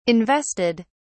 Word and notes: invested: British English

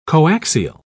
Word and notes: coaxial: British English

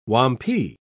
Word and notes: wampee: British English

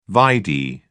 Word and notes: vide: British English

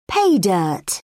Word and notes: pay dirt: British English

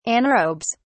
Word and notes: anaerobes: British English

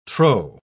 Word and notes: trow: British English

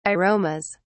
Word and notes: aromas: British English

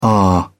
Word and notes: ah: British English

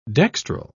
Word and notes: dextral: British English

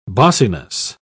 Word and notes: bossiness: British English

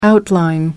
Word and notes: outline: American English

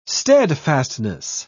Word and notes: steadfastness: British English

